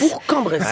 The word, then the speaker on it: Bourg-en-Bresse: Bourg-en-Bresse. Attention, parce que je vais me faire engueuler en arrivant si vous prononcez pas bien. – Bourg-en-Bresse. Voilà. D'accord.